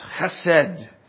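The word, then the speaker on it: chesed (חסד): It is the term in the Hebrew pronounced chesed, God's chesed, and that is his covenantally faithful mercy.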